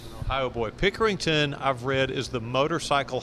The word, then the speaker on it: Pickerington: I hope I say this right. He's the pride of Pickerington, Ohio. Is that correct, Pat? – Yeah, that's correct. That's how you say it.